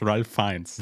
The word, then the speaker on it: Ralph Fiennes: Den spricht man doch nicht Ralph Fiennes aus. Hat mich über die lustig gemacht. Turns out, äh, Ralph Fiennes heißt der Mann nicht. Der heißt Ralph Fiennes.